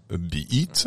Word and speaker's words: beat: C'est un mot valise qui mélange beat, prononcé beat. – Ah oui, pas à la française, voilà.